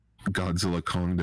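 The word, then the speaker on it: Godzilla x Kong: With the release of Godzilla Kong, the New Empire, yes, the X is silent.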